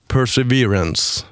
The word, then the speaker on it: Perseverance: Jag har svårt att uttala det här namnet. Därför vill jag inte göra det i nationell radio. Ja. Perseverance. – Perseverance. – Det lät väl lite ganska bra.